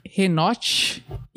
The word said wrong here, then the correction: Enoque: e Elias, eles teriam sido levados. – Escreve isso. Enoque. Enoque, né? E Enoque.